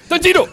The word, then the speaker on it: Tanjiro: I think it's Tanjiro because they'd be like, Tanjiro. – Oh, that is, yeah, they might. – They do. – So, how you say it? – Tanjiro.